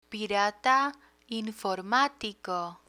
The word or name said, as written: pirata informatico